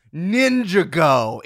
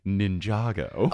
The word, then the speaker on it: Ninjago: Lego, ninja, Ninjago. It rhymes with indigo. So people already have the word association. – But we'll pronounce it Ninjago.